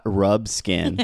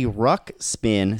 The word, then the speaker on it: Ruxpin: It's Ruxpin. It's not Rubskin.